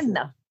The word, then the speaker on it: Seine: Is it Seine? – Yeah, yeah, yeah, yeah. The okay. Seine, the Seine, but it's okay. Seine.